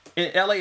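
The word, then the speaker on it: ELHAE: And LA is spelled, for those who know, it's E-L-H-A-E.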